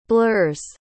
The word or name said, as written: blur's